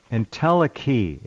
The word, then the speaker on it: entelechy: Entelechy. It's pronounced entelechy.